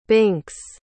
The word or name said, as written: binx